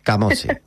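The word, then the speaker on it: Camozzi: Camochi.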